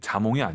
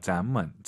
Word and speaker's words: 咱们: 네, 자몽이 아니에요. 咱们, 咱们, 이성경성.